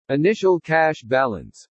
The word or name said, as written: initial cash balance